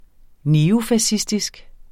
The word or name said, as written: neofascistisk